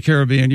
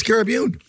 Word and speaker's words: Caribbean: Now, I understand if you're in the Caribbean, you pronounce it Caribbean. But if you're going to the Caribbean, you say Caribbean.